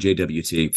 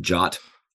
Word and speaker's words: JWT: So, and Gavin, thank you for saying JWT. For some reason, I just can't get my head around that people pronounce it JOT.